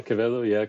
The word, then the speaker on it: Quevedo: Is that good, my pronunciation on that? – Absolutely Quevedo. Good, yeah. Terrific. Yeah, Quevedo, Quevedo. Yeah.